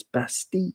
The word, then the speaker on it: Bastille: Like Bastille doesn't exist in French. It's Bastille, same kind of thing, double L. Pronounce it like a Y.